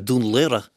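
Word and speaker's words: Dún Laoghaire: Hilary Swank's attempt at pronouncing Dunleary, in fairness, not a lot of people can pronounce it here either, Dunleary, was absolutely butchered in P.S.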